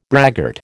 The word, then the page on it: braggart: American English